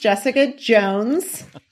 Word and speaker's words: Jessica Jones: My name is easy to pronounce, Jessica Jones, Mark Strachewski.